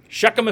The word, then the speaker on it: Shechem: Shechem if you got him. And what happens is Joseph will not get his own tribe, but he will get his own town where he'll be buried in Shechem.